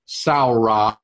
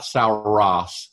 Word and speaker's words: saura: Just to be a little bit specific here, in the word dinosaur, the saur comes from a Greek word saura or saure, not sauros. Sauros was a horse mackerel. It has an A or a long E on the end.